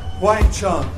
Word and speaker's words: Wang Chung: We are called Wang Chung. That's how you say it.